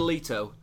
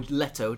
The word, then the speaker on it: Leto: Let's start with the big headline, which is Jared Leto, or Leto, depending on how you want to pronounce it, as our new Joker.